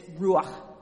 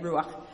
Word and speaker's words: ruach: So the Hebrew word for this is, it's ruach. That's just how you would find it written out in the Bible. That's how you say it, ruach.